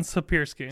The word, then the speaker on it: Siepierski: I got your name right. – It's close enough. – Where was it? – It's Aaron Siepierski.